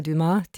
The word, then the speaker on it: Dumas: Das Buch heißt Mein Leben ist Musik, die 21 wichtigsten Frauen der klassischen Musik von Christina Dumas. Die spricht sich natürlich französisch aus, der Nachname. Christina Dumas,